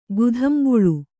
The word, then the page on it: ಗುಧಂಬುಳು: gudhambuḷu